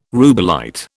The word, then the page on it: rubellite: British English